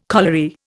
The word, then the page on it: colliery: Indian